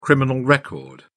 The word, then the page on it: criminal record: British English